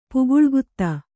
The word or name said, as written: ಪುಗುಳ್ಗುತ್ತ